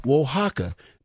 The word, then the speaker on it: Oaxaca: Mexicans who hail from the state of Oaxaca. Now, you guys will be proud of yours truly. If you see how this word is spelled, I actually pronounced it right, Oaxaca.